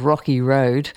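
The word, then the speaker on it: Rockie Rode: Before we come to Finn and Demo Gods, I'm going to play you an artist from New York called Rocky Road. Rocky, like the ice cream, but it's spelled R-O-C-K-I-E, and Rhodes like the microphone, R-O-D-E. So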